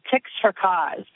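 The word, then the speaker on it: Tix4Cause: I was just signed on as the national spokesperson for an organization called Ticks for Cause. And that's called, it's spelled T-I-X for the number cause.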